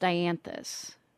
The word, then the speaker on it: dianthus: So I have, I think it's pronounced dianthus. – Yeah. – Is that right? Okay, yep.